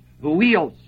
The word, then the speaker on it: huios: spelled H-U-I-O-S, which is pronounced huios.